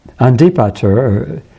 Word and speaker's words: Antipatros: So Antipatros, Andipater, NT becomes ND in modern Greek pronunciation. That's why I'm kind of going back and forth on the pronunciation.